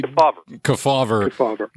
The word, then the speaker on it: Kefauver: You mentioned the 1950s Kefauver. I hope I'm saying that properly. – Kefauver.